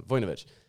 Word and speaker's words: Vojnović: Vojnović. I pronounced the O too much on that one. Vojnović.